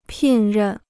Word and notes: 聘任: pìn rèn

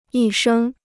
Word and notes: 一生: yī shēng

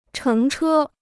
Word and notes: 乘车: chéng chē

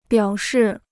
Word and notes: 表示: biǎo shì

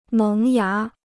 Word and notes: 萌芽: méng yá

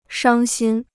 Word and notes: 伤心: shāng xīn

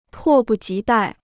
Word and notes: 迫不及待: pò bù jí dài

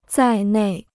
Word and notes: 在内: zài nèi

